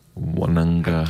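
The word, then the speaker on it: Nawaqanitawase: Nawuk Anitawazi. – No, the Q is actually pronounced as an NG, so it's Mark Wananga.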